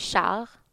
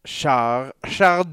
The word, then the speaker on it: Charles: By the way, c'est Charles. – Charles de Boisgouin.